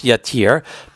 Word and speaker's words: Yatir: called Yatir, Yud Tuf, Yud Resh, Yatir. And in the cities of Levi'im, there's a city pronounced Yatir, but it's written there without that second Yud, and therefore this man suggests, argues, claims, asserts, whatever the right word would be, that those aren't the same city.